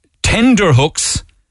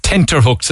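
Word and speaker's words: tenterhooks: Tender hooks. Tender. I'm on tender hooks, lads. It's tenter hooks, apparently.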